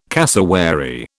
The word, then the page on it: cassowary: British English